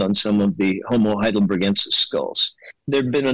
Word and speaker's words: heidelbergensis: Why does Homo heidelbergensis, or gensis, or gensis? How do you pronounce it? – Hard G.